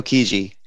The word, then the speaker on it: Chigi: Chigi? – Fabio Chigi. – Chigi.